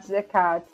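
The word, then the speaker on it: Kac: É Katz mesmo que fala, né? – É Katz.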